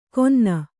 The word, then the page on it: ಕೊನ್ನ: konna